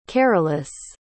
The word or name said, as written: carolus